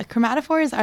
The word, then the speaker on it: chromatophores: So one way that octopi use their skin, how do you say this? Skin chromatophores? – Yeah, chromatophores are the cells that they use to change the color of their skin.